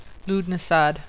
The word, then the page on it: Lughnasadh: Lood-nah-sahd